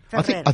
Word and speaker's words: Ferreri: Giussi Ferreri. Ferreri? – Ferrer. Yeah. – Is it Ferrero or Ferrer? Ferrer. – I think it's Ferreri. – Ferreri? – F-E-R-R-E-R-I.